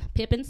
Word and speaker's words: Pippin: I'm, I'm making sure I'm saying it right. Yes, like, you like are saying Pippin. it right. Okay. Pippin, Pippin sauce is what it's called.